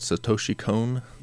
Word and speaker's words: Satoshi Kon: And the guy's name is, what, Satoshi Kon? – Yep.